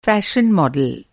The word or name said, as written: फैशन मॉडल